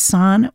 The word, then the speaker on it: Sahn: The musician's name, San, that is spelled S-A-H-N. This is San with Blonde.